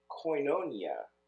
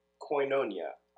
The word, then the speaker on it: koinonia: Koinonia, I think, is how it's at least koinonia pronounced by English speakers.